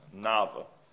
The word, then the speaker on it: Navarre: His name is spelled N-A-V-A-R-R-E, but it's pronounced Nava.